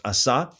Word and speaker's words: Asa: Then Asa, it's more correctly pronounced Asa, his son reigned in his place.